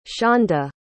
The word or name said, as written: shanda